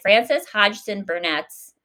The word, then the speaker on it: Frances Hodgson Burnett: Frances Hodgson Burnett's.